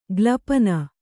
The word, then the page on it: ಗ್ಲಪನ: glapana